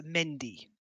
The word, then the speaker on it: Mehndi: It's a Mendy. It's actually misspelt in Kingdom Death. It should be M-E-H-N-D-I, but it's spelled M-H-E-N-D-I.